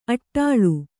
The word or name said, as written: ಅಡಳು